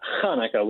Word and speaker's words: Hanukkah: So, well, in the Hebrew language, it's Hanukkah with the C-H, okay. C-H-A-N-U-K-A-H. The reality is that a lot of folks struggle with the H pronunciation, and so Hanukkah and Hanukkah have become pretty interchangeable in today's day and age.